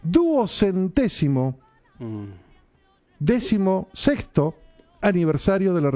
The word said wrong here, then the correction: ducentésimo: Para, ducentésimo, decimosexto.